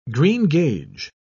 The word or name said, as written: greengage